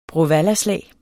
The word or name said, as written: bråvallaslag